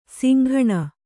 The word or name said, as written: ಸಂಗಿನಿ